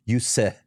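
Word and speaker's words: Juuse: I mean, I know there was a talk that Edmonton was hoping to get UC Soros, or I'm pronouncing that, Yuse, is how Yuse.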